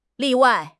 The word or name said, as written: 例外